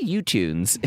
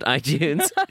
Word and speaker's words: iTunes: and I rented it recently on as you say, U-Tunes. It's called iTunes.